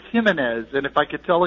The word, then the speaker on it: Jimenez: And I was told, it's Jimenez. And I said, well, then how do you pronounce his first name? And the smart aleck said, well, we call him Ho.